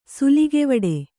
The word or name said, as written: ಸುಲಿಗೆವಡೆ